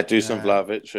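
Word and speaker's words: Dušan Vlahović: Yeah, Dusan Vlahovic.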